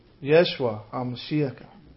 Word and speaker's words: Yeshua HaMashiach: In Hebrew, Yeshua HaMashiach. That is how Jesus the Messiah is pronounced.